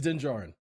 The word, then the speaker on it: Din Djarin: I think it's Din Djarin. No, it's Din Djarin. – Okay. – Like Dumb Djarin.